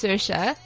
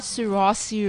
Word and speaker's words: Saoirse: So that's how you say her name. It's Saoirse. It's not Swarovski. – Swarovski. So what? Swarovski. – That's how you spell it. S-A-O-R-I-S-E.